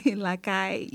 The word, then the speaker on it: lakay: L'acaille, you know, L-A-K-A-Y. You can spell it that way.